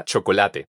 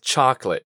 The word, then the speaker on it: chocolate: Primero vamos a dividirla en dos, eliminando la segunda O. Entonces, repite después de mí. Choc. Bastante fácil, ¿cierto? Ahora, en la segunda parte de la palabra nos queda latte. Esto lo vamos a pronunciar así. Let. Entonces, al juntarlo todo, nos queda chocolate.